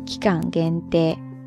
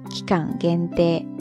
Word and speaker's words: 期間限定: 汉字呢写作期间限定，发音呢是期間限定、期間限定、期間限定ですね。在这里呢，它的意思就是特定在某一段时间内才发售的一些商品了。期間限定ですね。